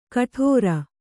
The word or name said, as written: ಗಡುರ